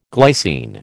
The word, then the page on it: glycine: American English